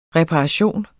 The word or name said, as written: reparation